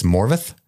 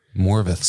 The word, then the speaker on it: Morfydd: That is not her name. At least that's not how you pronounce it. – Okay. – It is. And I'm probably going to butcher even this correction, but I believe it's Morvith. – Morvith. – So even though it's M-O-R-F-I-D-D Y-D-D, it is V and T-H that you use as the sound, like Morvith.